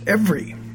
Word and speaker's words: every: That it's pronounced every. It's two syllables, even though from the spelling it looks like three. The second E is silent. The word could have been spelled E-V-R-Y.